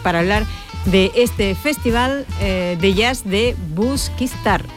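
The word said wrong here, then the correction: Busquístar: El acento. Me ha faltado el acento. Busquistar.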